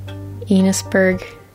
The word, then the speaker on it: Enosburg: Enosburg. Enos rhymes with Venus.